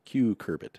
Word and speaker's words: cucurbit: a foliar-applied insecticide brassica leafy fruiting vegetable and curbit crops, or curcubit, cucurbit, I think it is pronounced, and Zavalgo, a foliar-applied insecticide for potato and tree fruit pest management.